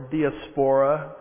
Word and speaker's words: diaspora: That is the word diaspora, or diaspora, depends on which syllable gets the emphasis, as I like to say.